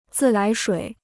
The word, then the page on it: 自来水: zì lái shuǐ